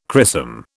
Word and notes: chrisom: British English

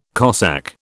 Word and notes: Cossack: British English

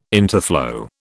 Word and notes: interflow: British English

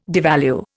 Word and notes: devalue: Indian

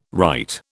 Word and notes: wright: British English

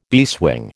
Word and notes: beeswing: American English

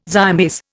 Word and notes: zymase: Indian

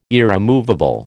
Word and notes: irremovable: American English

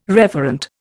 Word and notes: referent: Indian

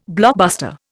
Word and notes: blockbuster: Indian

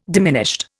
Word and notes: diminished: Indian

